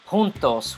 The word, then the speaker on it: juntos: Junto, as you know or may not know, is actually derived from the Spanish word juntos, which is spelled with a J but pronounced with an H.